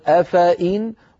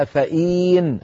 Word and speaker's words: أفائن: يعني القراءة العشر كلها تقول أفائن وليس فيها أفائين، هذه الياء لا تلفظ أبدا.